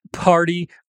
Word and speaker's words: party: As palavras party, water, better e totally, embora sejam escritas com T, são pronunciadas com o som de R. Em inglês, geralmente quando temos a letra T entre duas vogais, como em water, ou temos um T depois de um R e antes de uma vogal, como em party, ela pode ser pronunciada com um som de R. O mesmo som do R que vocês têm, por exemplo, na palavra para.